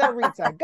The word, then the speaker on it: Rizza: Oh, Rizza. Think of pizza. – Got it. Pizza, Rizza. Got it.